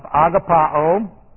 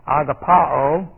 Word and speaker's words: agapao: We have agapao, or agape, A-G-A-P-A. Agape, that's the cognate noun. Agapao, A-G-A-P-A, long O.